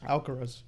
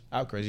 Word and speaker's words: Alcaraz: Am I saying his name right? Alvarez or Alcaraz? – Alcaraz. – Alcaraz, yeah, I thought so.